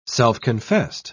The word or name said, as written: self-confessed